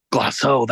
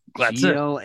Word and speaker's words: Glaceau: Glasso? – Glasso, that's it. That's it. – Glaceau.